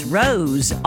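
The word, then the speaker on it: Rose Royce: I think it called it Rolls Royce, but it's Rose, R-O-S-E, Royce, called Car Wash.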